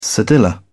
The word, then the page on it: cedilla: British English